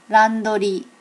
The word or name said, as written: randori